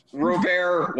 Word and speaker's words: Robert: Robert one day. Lift off. They just have to pronounce their T's.